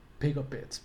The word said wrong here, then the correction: pygopodids: but if you can. – Another podid in there? – Pygopodid, that's what I think. Pygopodids too.